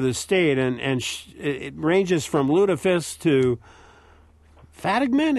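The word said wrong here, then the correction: fattigmann: is that a way to pronounce it? Fatigman? – Fatiman.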